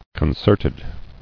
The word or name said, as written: Concerted